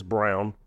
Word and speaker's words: Braun: It's spelled like Braun. I don't know why he says Brown, but anywho, he's good.